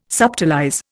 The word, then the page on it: subtilize: Indian